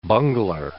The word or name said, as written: bungler